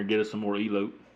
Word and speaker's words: Elote: Elote. – Elotes.